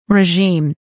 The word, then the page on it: regime: rəʒíːm; レジーム